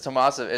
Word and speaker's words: Tomasevich: It's just Tomasevich. It's pretty like O-V-I-C-H, like just how it looks, just Thomas and then Ovich.